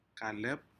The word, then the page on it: kalep: Aneityum